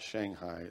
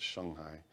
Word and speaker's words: Shanghai: And I'm saying it correctly. It's not Shanghai, it's Shanghai. And I finally got myself in the habit of saying that, because it's like saying Puyallup here. You know, you don't want to say it wrong.